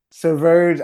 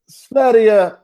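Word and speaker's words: Sverige: Severge Island. I know Severge is not how you say it in Swedish, but that's how I pronounce it.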